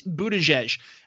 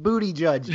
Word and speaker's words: Buttigieg: It is John McAfee. It is Amy Klobuchar. It is Pete Buttigieg. I swear to God, it's Pete Buttigieg.